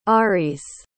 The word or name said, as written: Aariz